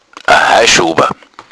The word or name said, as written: schober